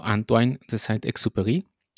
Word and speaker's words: Saint-Exupéry: El escritor del Principito fue ni más ni menos que un gran piloto de guerra llamado Antoine de Saint-Exupéry, no sé si lo pronuncié bien, Nico, tú corrígeme, tú que eres ahí el experto en el francés. Sí, buena pronunciación, 8 de 10.